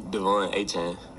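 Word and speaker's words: Achane: how to pronounce his name. So let's listen to it, and we'll see what we hear here. – Hey, Brent Zwerneman, Houston Chronicle. Okay, I know they just pronounced it, but I'm going to have you pronounce your first and last name once and for all for us, if you don't mind. – Devon A-Chan.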